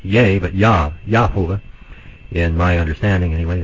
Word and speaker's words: Yah: If you look in your Strong's Concordance or Hebrew lexicon, 3050, and then the one for Jehovah, of course it's not pronounced with a J anyway, it should be Yah, Yahovah, and Y-A-H, we just read there.